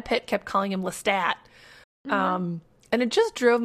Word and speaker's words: Lestat: But I do think, after doing some minor sleuthing on the internet afterwards, that it should be pronounced Lestat. – Well, yeah, because it's Creole, it's French. – Right, and there's no, like, E after it. Okay, great. So we're all on the same page. It's Lestat, everyone.